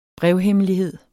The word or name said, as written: brevhemmelighed